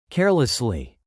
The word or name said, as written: carelessly